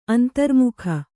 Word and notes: ಅಂತರ್ಮುಖ: Kannada

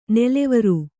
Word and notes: ನೆಲೆವರು: nelevaru

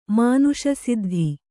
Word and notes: ಮಾನಸಸಿದ್ಧಿ: mānasa siddhi

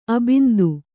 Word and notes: ಅಬಿಂದು: Kannada

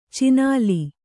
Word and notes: ಚಿನಾಲಿ: cināli

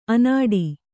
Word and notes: ಅನಾಡಿ: anāḍi; kannada

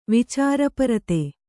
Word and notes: ವಿಚಾರಪರತೆ: vicāra parate

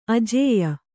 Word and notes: ಆಜ್ಯ: ājya

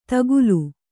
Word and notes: ತಕ್ಕಿಲೆ: takkile

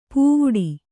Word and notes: ಪೂವುಡಿ: pūvuḍi

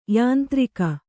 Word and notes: ಯಾಂತ್ರಿಕ: yāntrika

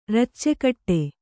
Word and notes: ರುಚಿಕಟ್ಟು: rucikaṭṭu

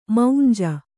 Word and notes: ಮಂಜು: Kannada

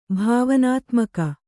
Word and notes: ಭಾವನಾತ್ಮಕ: bhāvanātmaka

